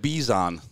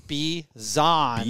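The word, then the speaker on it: Bijan: Again, it, B, Bizon. Uh, That's right. Sorry. – No. No. – Don't B, don't apologize. B e z e z. – Like, like Steve Zahn the actor, but Bizon. Bizon.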